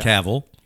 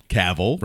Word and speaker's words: Cavill: Cavill. – Cavill. – Rhymes with travel.